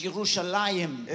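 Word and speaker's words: Yerushalayim (ירושלים): You know how you say Jerusalem in Hebrew? ¿Sabes cómo se dice Jerusalén en hebreo? It's Yerushalayim. Es Yerushalayim.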